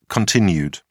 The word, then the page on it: cont: British English